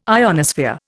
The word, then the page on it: ionosphere: Indian